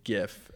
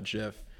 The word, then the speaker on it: GIF: And so he's like, if it's short for graphics, it's a hard G. And so it's animated GIF and not JIF.